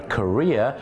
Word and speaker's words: career: C A R W E R career ออกเสียงว่า career คล้ายๆกับประเทศเกาหลีเนี่ยนะครับอาชีพเนี่ยนะแต่บ้านเราเนี่ยนะครับไปออกเสียงว่า carrier เ